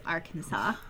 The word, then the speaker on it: Arkansas: No, it's Arkansas. Don't ask me why doesn't the S is silent on that one.